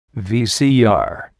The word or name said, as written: VCR